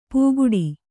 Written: ಪುಗುಡಿ

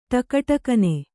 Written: ಟಕಟಕನೆ

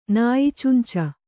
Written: ನಾಯಿಚುಂಚ

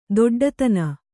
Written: ದೊಡ್ಡಿತನ

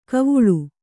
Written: ಖಾವುಳ

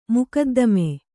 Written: ಮೊಕದ್ದಮೆ

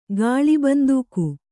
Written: ಗಾಳಿಬಂದೂಕು

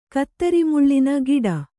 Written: ಕತ್ತರಿಮುಳ್ಳಿನ ಗಿಡ

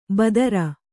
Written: ಭದ್ರೆ

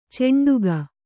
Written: ಚಂಡಿಗೆ

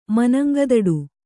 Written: ಮನಂಗದಡು

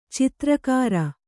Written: ಚಿತ್ರಗಾರ

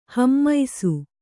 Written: ಹೇಮಿಸು

